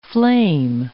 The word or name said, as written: flame